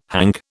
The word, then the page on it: hank: British English